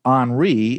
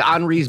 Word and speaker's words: Henri: And we also have a little system that just popped up, and that's Henri. It's spelled with like Henry, but with an I instead of a Y.